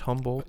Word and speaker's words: Humboldt: Uh, um, am I pronouncing it right? Humboldt. Yeah. – Humboldt, um, yeah.